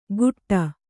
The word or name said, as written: ಗುಟ್ಟ